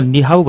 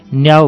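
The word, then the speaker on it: निहाउ (你好): नेपालमा निहाउ भन्ने बेला मानिसहरूले हाँसो उठ्ने तरिकाले उच्चारण गरेको सुनिन्छ। त्यसो त नेपालमा कुनै पनि भाषालाई तोडमोड गर्दै मौलिक बनाइदिने चलन पनि छ। अत निहाउलाई न्याउ पनि भनिदिने गरेको सुनिन्छ, जसको एउटा अर्थ पिसाब गर्नु भन्ने हुन्छ।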